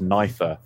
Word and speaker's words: Knifer: I didn't want to call him Knifer, but apparently it is pronounced Knifer, as in with a knife.